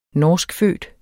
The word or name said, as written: norskfødt